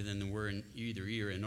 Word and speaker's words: Erie: As is held in the Erie, and they pronounce it locally Erie, in Your Honor, regardless of the spelling, in the Erie and Sterling decisions, which are factually and legally undistinguishable from what is here today.